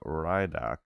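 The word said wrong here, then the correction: Reidak: His name is Radak, not Rydok.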